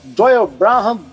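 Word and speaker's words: Doyle Bramhall: Me ajuda aí na pronúncia, que eu me enrolo. – Doyle Branham II. Branham, isso.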